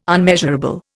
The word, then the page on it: unmeasurable: Indian